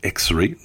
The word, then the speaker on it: EX2.RE: On dit qu'il faut prononcer X-Ray, donc prononçons X-Ray.